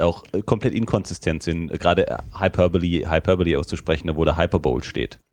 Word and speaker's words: hyperbole: Im Grunde der erste Satz schon, think hyperbole rhymes with superbowl und das wird aber hyperbole ausgesprochen, was komisch ist, aber ist nur mal so.